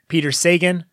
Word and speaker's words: Sagan: Peter Sagan, not Carl Sagan's brother.